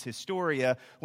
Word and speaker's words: historia: My kids, because they've had Latin at school, will correct me on the pronunciation on the drive home. But let's just say the pronunciation is historia.